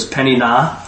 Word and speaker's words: Peninnah: So blue letter Bible, we'll show you how to pronounce it. So it's Peninnah.